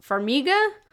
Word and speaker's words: Farmiga: But yeah, and I'm going to pronounce that wrong. Farmiga? – Yeah, she's Vera Farmiga's little sister.